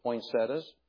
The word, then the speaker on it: poinsettia: The Rons helped us by bringing the poinsettias. If pronounced as spelled, it's poinsettia, I realize.